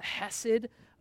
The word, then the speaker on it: chesed: The chesed of the Lord, right? You got to get some phlegm there to even pronounce it correctly in the Hebrew. But the hesed of the Lord, his steadfast love, will not depart from him.